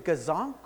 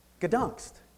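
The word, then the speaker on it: Gdańsk: Okay, tell us, is it Gazonk or... Gdansk. Yeah, Gdansk. That's how you pronounce it today.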